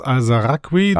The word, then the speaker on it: Alazaraqui: Carlos Alazaraqui. Wer ist das? – Carlos Alazaraqui. – Da ist ein A zu wenig, zu viel in meiner Aussprache, ja.